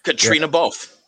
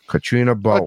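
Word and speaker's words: Caitríona Balfe: It's not spelled like Katrina, but it's pronounced like Katrina. It's spelled like Katriana, and Balfe is Balfe.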